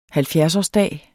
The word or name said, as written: halvfjerdsårsdag